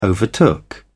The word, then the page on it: overtook: British English